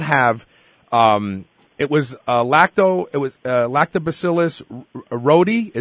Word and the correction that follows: reuteri: is that what it was called? – It's pronounced, reuteri or reuteri, yeah.